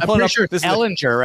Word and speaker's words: Ehlinger: It is L is the um, emphasis. It's Ehlinger. G U R. Oh, I win. I win. I had the correct Ehlinger. pronunciation. Ehlinger. All right, there we go.